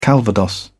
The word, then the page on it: calvados: British English